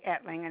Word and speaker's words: Etling: Etling, and that's E-T-L-I-N-G.